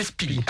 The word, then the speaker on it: SPDY: Et ça s'écrit S-P-D-Y. Hein. – C'est ça, mais ça se prononce Speedy.